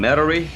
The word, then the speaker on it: Metairie: is it Metairie, Louisiana? – They call them Metairie.